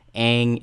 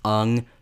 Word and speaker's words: Aang: Aang is Ung, Sokka, Soka, Uncle Iroh, Uncle Iroh, some dumb...